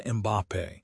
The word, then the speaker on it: Mbappé: It turns out that when players like Kylian Mbappé, pronounced Kylian Mbappé, and Ousmane Dembélé, pronounced Ousmane Dembélé, can generate power from almost no space, expected goals models simply stop mattering.